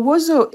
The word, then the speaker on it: wozo: One is the word, I believe it's pronounced wozo, which is a bit like resilience. Am I saying that correctly? – Yeah. – What does the word mean to you? – Well, wozo, it's like an irrepressible weed that grows in marshlands and riverbeds in Haiti.